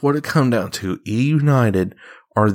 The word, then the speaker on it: eUnited: Last and final subject of today's part of the Morgan Man Sports Podcast, and that is your Call of Duty World Champions E United. Yes, that's right, guys. I'm saying that right. It's not 100 Thieves. It's not Optic Gaming. It's not Gen G. It is E United.